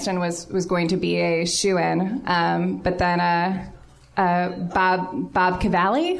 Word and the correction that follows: Bobby Cannavale: Bobby Cannavale from Boardwalk Empire won against John Hamm and all the guys from Breaking Bad, and also with Best Supporting Actress?